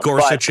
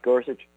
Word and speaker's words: Gorsuch: Well, I think Gorsuch has been the front runner for this. – There's a guy from up in Pittsburgh that they've been talking about as well. But you're right, Gorsuch, and I hope we're pronouncing it right.